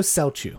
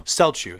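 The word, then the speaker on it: Celchu: Tycho Welcho, is that how you say it? – Tycho Selchu. – Selchu, sorry.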